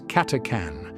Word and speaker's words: Catachan: The subject of today's sermon will be focused on one such planet, named as Catachan, which I must point out is often pronounced as Catacan, though even our official sources will sometimes use these pronunciations interchangeably.